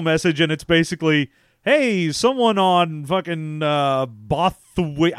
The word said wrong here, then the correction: Bothawui: Bothawui. – Bothawui. – Bothawui, because they're Bothans. – Yeah, but so, the way that it's spelled, I was like, is that Bothaway? – No, it's Bothawui. It's Bothawui.